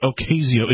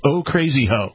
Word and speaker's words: Ocasio: You have to pronounce it right. It's not Ocasio. It's Ocasio.